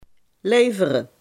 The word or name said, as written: Leveroy